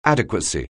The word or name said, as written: adequacy